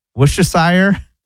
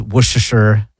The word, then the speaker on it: Worcestershire: So you don't say the R? You don't say Worcestershire? – No.